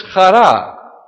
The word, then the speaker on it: chara: Chara is, in English, when you spell it, you will spell it H-A-R-A, chara, which is not Greek.